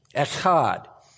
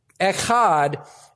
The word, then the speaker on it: echad: Echad, E-C-H-A-D, if you were going to do a transliteration in English.